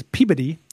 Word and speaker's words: Peabody: Folks here in Mass, especially in the eastern half of the state, have a lot of idiosyncratic pronunciations. Quincy is Quincy, Woburn is Woburn, Peabody is Peabody.